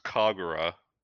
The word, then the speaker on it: Kagura: It's pronounced Kagura, Senran Kagura.